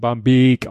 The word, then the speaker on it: Barmbek: Ein Dehnungs-C ist, normalerweise sagt man ja CK, dann wird der Beck. Vokal davor kurz ausgesprochen, ja. also Beck. – Ja. Aber ein C kann auch ein Dehnungs-C sein und wird eben Barmbek ausgesprochen.